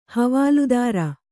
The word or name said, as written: ಹವಾಲ್ದಾರ